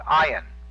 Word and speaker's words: Ayin: The next letter is ion, A-Y-I-N. It's a silent letter. It has no sound at all.